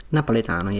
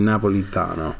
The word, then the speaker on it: Napoletano: Napoletano, yes. – Okay. Yeah. For the longest time, I was saying Napolitano, but it's Napoletano.